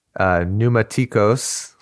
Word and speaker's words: Pneumatikos: Bodies will lay in the public square of this great city, which is Pneumatikos. – Yeah. – Is that how you pronounce it? – Yeah, that's right, yeah.